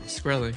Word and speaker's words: Shkreli: How do you, by the way, how do you pronounce his last name? – Shkreli. – Shkreli? – Yeah.